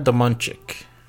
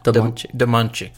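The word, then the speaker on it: Domińczyk: Dagmara Domunchik. – Dagmara... – Domunchik. – Okay. – Yeah, last name is spelled Dominczyk.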